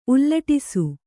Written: ಉಲ್ಲಟಿಸು

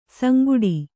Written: ಸಂಗುಡಿ

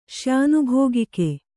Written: ಶ್ಯಾನುಭೋಗಿಕೆ

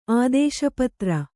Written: ಆದೇಶಪತ್ರ

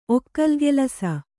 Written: ಒಕ್ಕಲ್ಗೆಲಸ

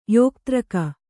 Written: ಯೋಕ್ತ್ರಕ

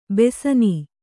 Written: ಬೆಸನೀ

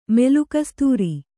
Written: ಮೆಲುಕಸ್ತೂರಿ

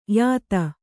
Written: ಯುತಿ

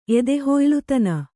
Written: ಎದೆಹುಯ್ಲುತನ

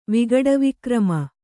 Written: ವಿಗಡವಿಕ್ರಮ

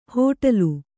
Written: ಹೊಟೇಲು

